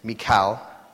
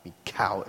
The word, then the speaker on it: Michal: We have these two players, and one is David's wife, and it's Michal. Michal, it's the emphasis on the K there. Michal.